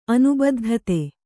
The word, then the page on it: ಅನುಬದ್ಧತೆ: anubaddhate